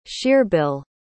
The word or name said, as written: shearbill